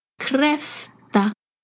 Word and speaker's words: kräfta: Since we are talking about crayfish, naturally the word for the day is kräfta, crayfish.